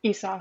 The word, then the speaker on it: Esau: Esau. – Oh. – Yeah, which is interesting. – Esau is how you pronounce it.